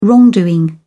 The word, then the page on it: wrongdoing: British English